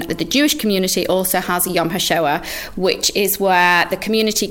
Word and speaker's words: Yom HaShoah: In Manchester, Yom HaShoah, yeah, in Manchester.